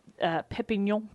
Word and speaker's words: Perpignan: In Perpignan prison. – Oh, well pronounced.